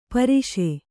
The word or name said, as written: ಪರುಸ